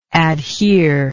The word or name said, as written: adherer